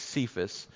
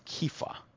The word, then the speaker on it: Kepha: Now, people pronounce this as Cephas. It's Kepha.